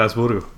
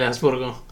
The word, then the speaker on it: Augsburgo: ¿Cómo se dice esto? Augsburg. – El Augsburgo. – Sí, el Augsburgo.